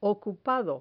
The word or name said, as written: ocupado